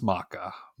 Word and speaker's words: Macha: But it's pronounced Maka.